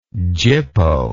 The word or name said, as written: gypo